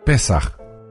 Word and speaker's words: Pesach: Se la conoce como la celebración del Pesach y se pronuncia Pesaj, palabra que viene de la raíz hebrea que significa saltar o pasar por alto.